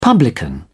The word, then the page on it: publican: British English